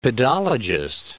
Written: pedologist

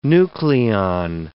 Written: nucleon

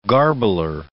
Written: garbler

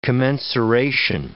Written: commensuration

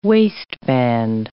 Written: waistband

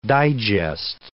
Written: digest

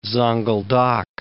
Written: Zonguldak

